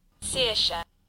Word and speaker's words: Saoirse: Saoirse. – Saoirse. – We can Google it. – Saoirse. – Saoirse.